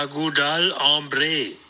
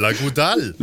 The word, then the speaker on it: La Goudale: Also aussprechen tut man es La Lagudal Ambre. Aber was das heißt, das weiß ich nicht. Alles klar. Jetzt müssen wir das sagen. La Goudale.